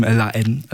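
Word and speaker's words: La Haine: Den französischen Film L.A.N. Empfohlen. Mhm. Habe ich richtig ausgesprochen? Ja. Das H ordentlich weggelassen, so wie es in der französischen Sprache. L.A.N.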